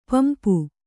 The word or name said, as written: ಪಂಪ